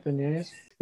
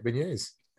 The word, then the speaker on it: beignets: That's how you say beignets. Yeah, that's how you say it, beignets.